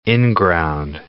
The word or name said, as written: inground